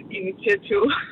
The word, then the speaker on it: initiativ: Nej, altså, min veninde sagde, at når jeg skal udtale det, så jeg skal udtale det, ligesom jeg nyser, initiativ.